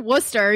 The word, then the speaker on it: Worcester: Did I pronounce Worcester correctly, or is it Worcester? – Have you been practicing Worcester? You know, you had it right.